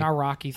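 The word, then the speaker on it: Nawrocki: Naraki. Thank you. I can never pronounce that name correctly. – You don't have to pronounce the W.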